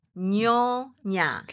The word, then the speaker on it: Nyonya: Nyonya. – Can you spell that for me? – Okay. N-Y-O-Y-A.